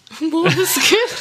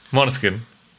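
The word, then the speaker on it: Måneskin: Moneskin. Moneskin.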